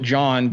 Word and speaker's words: Jan: they pronounce it John, but it's spelled Jan.